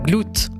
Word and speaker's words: Glute: C'est un titre du nouvel album de District 5 qu'on prononce donc Glute. – Glute, voilà, comme les muscles fessiers en anglais, mais ça n'a rien à voir.